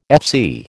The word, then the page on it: FC: American English